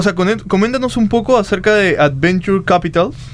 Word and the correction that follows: Venture Capital: ¿Lo dije bien? – ¿Sí? Bueno. – Venture Capital.